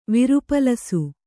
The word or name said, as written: ವಿರುಪಲಸು